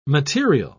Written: material

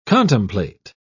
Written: contemplate

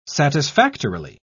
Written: satisfactorily

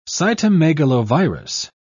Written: cytomegalovirus

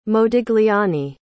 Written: modigliani

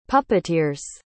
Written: puppeteers

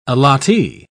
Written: allottee

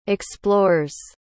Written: explores

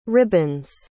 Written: ribbons

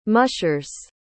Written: mushers

